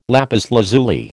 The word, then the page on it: lapis lazuli: American English